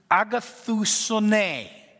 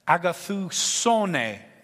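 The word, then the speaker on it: agathousone (ἀγαθωσύνη): And this word goodness in Greek is agathousone. Agathousone, that's what it is. Emphasis on the so.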